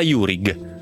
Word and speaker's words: Jurig: Jurig, senza la J o la Y, e tantomeno la H dopo la G. È molto semplice, perché è un cognome italiano. Si legge come si scrive e si scrive come si pronuncia.